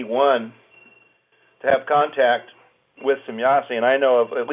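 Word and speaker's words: Semjase: And as a contactee, you had contact with Semyasi. Am I saying the name right? – Yeah, Semyasi. – Semyasi. – That's a German pronunciation.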